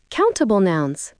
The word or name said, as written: countable nouns